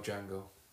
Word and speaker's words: Django: Would you say that about Django? Would you? – Well, yeah, there's no D at the beginning of that. – The D is silent.